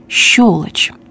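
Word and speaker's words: щёлочь: Для правильной постановки ударения. Свекла, шофер, неровен час, новорожденный, скабрезный, щелочь.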